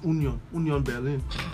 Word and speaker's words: Union Berlin: Union Berlin They said it's Union Berlin.